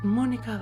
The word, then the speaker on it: Monika: Mein Name ist Monika Westphal.